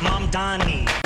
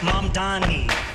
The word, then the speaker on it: Mamdani: M-A-N-D-A-N. – The name is Mom Donnie. That's it. That's the one. – The name is Mom Donnie. M-A-M-D-A-N-I. The name is Mom Donnie. M-A-M-D-A-N-I. You should learn how to say it. M-A-M-D-A-N-I. You should learn how to say it. M-A-M-D-A-N-I.